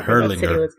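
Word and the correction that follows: Harlingen: Herlingen. – Okay, I was wrong again.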